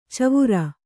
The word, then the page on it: ಚವರ: kannada